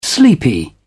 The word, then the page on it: sleepy: British English